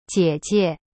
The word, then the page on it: 姐姐: jie jie